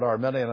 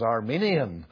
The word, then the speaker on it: Arminian: This word Arminian, it's not the country of Armenia. I hear some people pronouncing the word Arminian as Armenian.